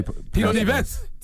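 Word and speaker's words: Tito Nieves: He said that is actually a sample from Tito Neves. Did I pronounce that right? How you pronounce it, Mr. Puerto Rican? – I'm sure you Tito Neves. – Tito Neves. – Tito Neves. Yes.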